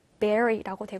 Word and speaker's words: bury: 생긴 것과 스펠링과 발음이 좀 다르기 때문에 주의하셔야 돼요. 자, 발음은 bury라고 되고요.